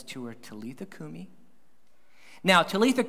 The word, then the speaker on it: Talitha kumi: So when you say that, just say Talitha kumi. Just say that right now, Talitha kumi. All right, two people did that. Just try it again. Talitha kumi.